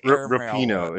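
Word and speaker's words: Rapinoe: And for Megan Rapone in her last game to Rapino, it's pronounced Rapino.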